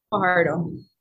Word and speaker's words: Fajardo: Fajardo. – Fajardo? – Yes. – The J pronounced H, right? – Yeah.